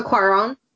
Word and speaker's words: Cuarón: I mean, it was the director of Gravity, Alfonso Cuarón. I think I'm pronouncing that wrong. – Alfonso Cuarón.